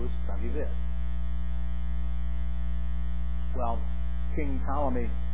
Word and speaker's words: Ptolemy: Problem was, there was a pharaoh at the time of Euclid, and his name was Ptolemy the First, spelled with a P. P-T-O. Okay, Ptolemy.